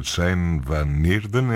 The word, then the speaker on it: Chaim van Nierden: Houve uma coisa que me chamou a atenção no programa da edição de 2026, que tem a ver com o pianista Chaim van Nierden, é assim que se pronuncia.